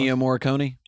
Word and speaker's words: Ennio Morricone: Ennio Morricone? – Yeah, he did the music.